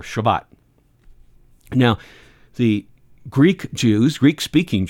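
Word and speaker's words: Shavuot: Now, the law of Moses said 7 weeks later, there was another festival that you should go to, and this one is pronounced Shavat. And it literally means weeks, because it was 7 weeks.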